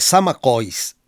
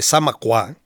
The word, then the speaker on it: Zamacois: La Golondrina fue compuesta probablemente antes de 1870 por el veracruzano Narciso Serradell sobre versos de Niceto de Zamacois o de Zamacuá, dependiendo la pronunciación que elijan,